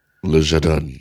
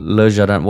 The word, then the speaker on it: Le Jardin: Le Jardin, yeah. – Oh, that's how you pronounce it in French, yeah? – Yeah, like in French. Yeah. – Le Jardin.